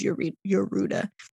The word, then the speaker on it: Yorda: It's spelled Yorda, but it says the pronunciation is Yoruda.